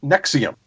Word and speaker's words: NXIVM: It's spelled N-X-I-V-M. – Nexium? – Pronounced Nexium.